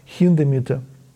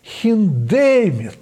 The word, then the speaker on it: Хиндемит: И как же она, думаю, скажет сейчас, «Хиндемит» по-русски или «Хиндемит»? Она для меня была эталоном произношения. И вот Анна Чехова вышла и сказала: «Пауль Хиндемит!» И я ни разу, ни до, ни после не слышал этого уникальнейшего ударения, и поэтому я на всю жизнь запомнил это.